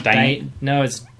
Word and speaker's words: dynamism: I remember reading this word to myself and having trouble pronouncing it. So I'm not surprised that it's a dynamism. – Dynamism? – It's dynamism. – How do you spell it? – D-Y-N. – Yeah. – A-M-I-S-M. Too many A's. – Dynamism? – Dynamism. – Dynamism. – Dynamism! There we go.